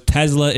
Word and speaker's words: Tesla: And I'm saying Tesla for that reason, because he says, as I'm sure you know, Tesla is Serbian, and the Serbian pronunciation is Tesla, as mm-hmm. if it were a Z. – With a Z sound.